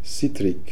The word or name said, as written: Sitrik